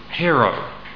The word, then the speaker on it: hairo: And the word here for taking it out of the way is hiero, which is spelled hero, H-A-I-R-O. hero.